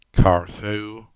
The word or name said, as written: Carthew